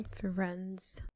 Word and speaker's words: Firenze: Firenze.